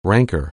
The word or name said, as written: rancor